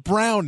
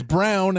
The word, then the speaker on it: Braun: it's pronounced Brown now. – Yep. – It's Brown. – With Michael. – Even though it's spelled Braun, we all know it's Brown.